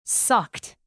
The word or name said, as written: sucked